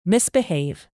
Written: misbehave

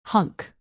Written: hunk

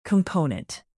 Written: component

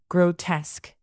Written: grotesque

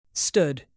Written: stood

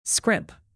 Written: scrimp

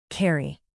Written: carry

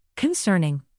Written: concerning

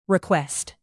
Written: request

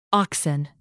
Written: oxen

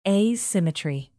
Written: asymmetry

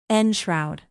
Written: enshroud

